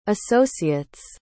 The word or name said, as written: associates